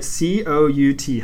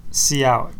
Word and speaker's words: cout: So on that, when in C++, when I write COUT, how do you say that? – C out. – Okay, that's how I say it as well. But some people say COUT.